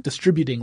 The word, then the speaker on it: distributing: It's a great means of distributing large files, right? Distributing large files. I put the wrong emphasis on the wrong syllable.